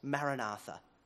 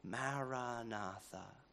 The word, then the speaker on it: Maranatha: We recommend a prayer phrase, Maranatha. Recite it as four syllables of equal length, Maranatha. Listen to it as you say it, Maranatha, gently but continuously, Maranatha, Maranatha.